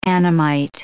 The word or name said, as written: Annamite